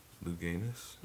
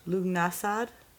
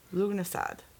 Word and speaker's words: Lughnasadh: Lughnasadh. Lughnasadh. – Luganus? – Lughnasadh. Lughnasadh. – Okay.